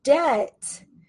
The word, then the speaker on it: debt: Now remember this word debt, it has a silent letter in it. We do not pronounce this B sound. It is silent, okay? So we do not say debt, debt. We say debt, debt. Pretend that the B is not even there, okay?